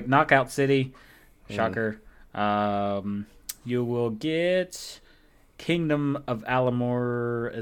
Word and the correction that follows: Amalur: Is that how you say it? Amalur, I think. – Amalur. – Yeah. – I know it was one of those vowels. Vowel combinations.